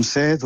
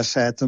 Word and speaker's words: set: Uns diuen set, uns diuen set, i jo recomanaria pronunciar set en veu oberta perquè les paraules noves monosilàbiques tendeixen a la vocal oberta, a la E oberta i a la O oberta.